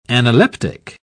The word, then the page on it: analeptic: British English